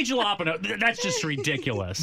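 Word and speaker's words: jalapeño: I mean, the correct pronunciation, if you're speaking Spanish, it's jalapeno, but...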